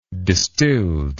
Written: distilled

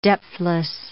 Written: depthless

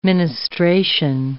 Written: ministration

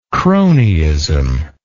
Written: cronyism

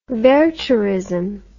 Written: wertherism

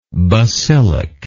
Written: basilic

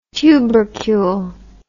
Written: tubercule

